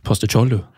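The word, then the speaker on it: Postecoglou: Uh, han hadde sikkert gjort det kjempebra, men jeg tror Poste, eh, poste som det egentlig skal uttales Poste Cholu? Poste Cholu.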